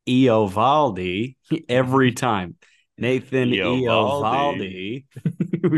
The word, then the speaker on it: Eovaldi: I watched the highlights of Eovaldi's major league debut with Vin Scully calling it.